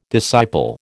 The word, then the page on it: disciple: American English